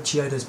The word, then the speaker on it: Chiodos: It's by Chidos. – Choidos. – I said Chiodos. – I don't know how to say it, okay? – It's Chiodos.